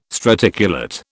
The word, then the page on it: straticulate: British English